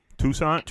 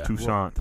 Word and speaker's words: Toussaint: I think Toussaint is a true point guard. I would like to see Toussaint. There's no R in his name. It's Toussaint.